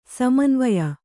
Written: ಸಮಾನವಾಯು

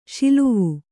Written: ಷಿಲ್ವೆ

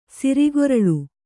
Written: ಸೋರ್ಗುರುಳು

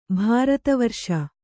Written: ಭಾರತವರ್ಷ

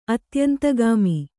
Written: ಅತ್ಯಂತಗಾಮಿ

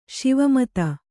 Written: ಶಿವಮತ

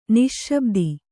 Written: ನಿಶ್ಶಬ್ದಿ

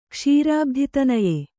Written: ಕ್ಷೀರಾಬ್ಧಿತನಯೆ

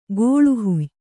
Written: ಕೋಳುಹುಯ್

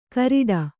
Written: ಕರಿಡ

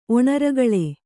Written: ಒಣರಗಳೆ

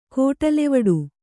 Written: ಕೋಟಲೆವಡು

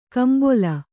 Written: ಕೆಂಬಲು

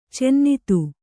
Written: ಚಿನ್ನಿಧಿ

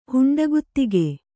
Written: ಹುಂಡಗುತ್ತಿಗೆ

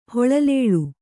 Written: ಹೊಳಲೇಳು

